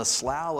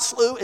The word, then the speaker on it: Slough: He lands in the Slough of Despond. And yes, that's how you pronounce that word, according to my sources. Derek Thomas being the main one says, it's not slough, it's slough.